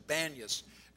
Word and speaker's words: Banias: That's why it's called Panias, or Banias, as it's pronounced in Arabic.